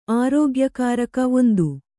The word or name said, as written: ಆರೋಗ್ಯಕಾರಕ